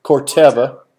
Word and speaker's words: Corteva: And that led to your career now, and I'm going to butcher this, but is it Corteva? – Corteva.